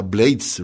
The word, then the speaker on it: Blades: Por cierto que el tío Rubén, a su apellido lo pronuncia Blades, Rubén Blades, y no es porque haya vivido muchos años en Nueva York,